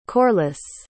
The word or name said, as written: Corliss